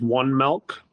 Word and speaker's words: Milc: And then the third one I would say is our feed software system, or our feed software, I guess, which is One Milk, and it's spelled M-I-L-C, and they're based out of California.